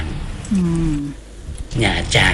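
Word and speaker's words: Nha Trang: หยาจาง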